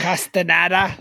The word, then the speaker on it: Castaneda: Castaneda.